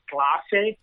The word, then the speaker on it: Clase: Well, Jonathan, and his last name is pronounced Clase. – Okay. – It's a classroom in Spanish.